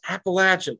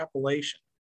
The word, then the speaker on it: Appalachian: And in your book, I remember that, you know, you were so adamant, like, it's Appalachian, it's Appalachian. And you kept reminding people, I guess, when you were in California, this is how you say it. It's Appalachian. It's never Appalachian. You know, we can tell who's not from here by how they pronounce exactly.